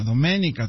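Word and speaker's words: Doménica: Domenica, Antonio, Domenica, con acento en la E, Antonio.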